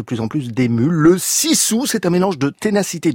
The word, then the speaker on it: sisu: Ça s'écrit S-I-S-U, mais avec la prononciation finlandaise, ça donne donc Sissou,